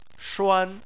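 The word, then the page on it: shuan1: tone 1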